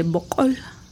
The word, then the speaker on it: bocol: sí, que tiene un sonido glotal, que quiere decir que es un sonido que no hay en español, un sonido que se pronuncia con la garganta, con la uh-huh. glotis, ¿no? Uh-huh. Haciendo mover la garganta, entonces, y se dice bocol.